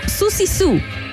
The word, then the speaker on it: Siouxsie Sioux: En ese instante, Susan Vallion decidió ser Siouxsie Sioux, o hay que pronunciarlo como Susie Sue, para la gente que esté más entendida en el tema.